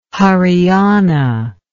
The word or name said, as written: Hariana